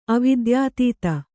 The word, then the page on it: ಅವಿದ್ಯಾತೀತ: Kannada